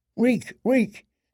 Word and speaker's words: Reek: Reek, reek, it rhymes with sneak."